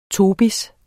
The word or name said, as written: tobis